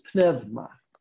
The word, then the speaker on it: pneuma: Now, the word wind here is pneuma. Pneuma. You know the word pneuma. Pneuma. Pneumatic tool. Right? You say, you didn't say that right. You're not supposed to say that P.